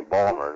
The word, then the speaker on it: Baltimore: Yeah, Baltimore. It's actually Balmer. It doesn't even have the I in it.